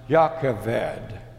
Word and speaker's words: Jochebed: In the Hebrew language, it is pronounced Jochebed.